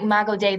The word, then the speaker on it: Imago Dei: Imago Dei, that's that image bearing perspective.